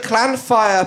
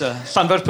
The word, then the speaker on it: Llanfair: Is it Clan Fire, Puck Gwyn, Gich Gok, Gerok, Wim, Draw, Book Clan, Tisili, or Gugu Goch? – No, it's Llanfair Puck Gwyn, Gich Gok, Gerok, Wim, Draw, Book Clan, Tisili, or Gugu Goch.